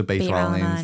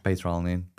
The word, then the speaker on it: beta-alanine: But also beta-alanine is really good as well. – How do you say it? – Beta-alanine. But if you want to be more American, you say beta-alanine.